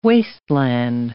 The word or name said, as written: wasteland